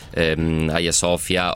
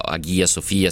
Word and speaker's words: Hagia Sophia: L'ultimo tassello sarebbe la riappropriazione, anche se in realtà è luogo particolarmente sincretico, Hagia Sophia, Hagia Sophia, secondo se la vogliamo pronunciare alla turca o alla greca, lo ricordavo, già basilica, e poi nel 1453 il sultano Fatih il conquistatore che la rese definitivamente moschea.